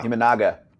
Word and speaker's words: Imanaga: Okay, I'll probably get this name wrong. Shota Imanaga. – Yeah. Imanaga.